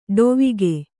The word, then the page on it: ಡವಿಗೆ: ḍavige